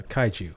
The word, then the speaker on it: kaiju: Is that pronounced Kaiju? – Kaiju. – Kaiju. Kentucky Kaiju.